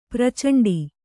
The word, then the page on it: ಪ್ರಚಂಡ: pracaṇḍa; Kannada